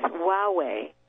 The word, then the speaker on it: Huawei: And they were working on a project with Huawei. Now, I'd like to spell that. It's spelled H-U-A-W-E-I.